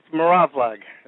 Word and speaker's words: Mravlag: Brother Victor, pronounce your last name. I may have slaughtered it. – No, you did okay. It's Mravlag.